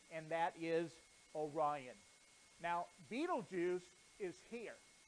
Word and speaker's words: Betelgeuse: And then my favorite is Betelgeuse. Betelgeuse. Now some people pronounce that different, but because I'm the speaker, that's how I'm going to pronounce it.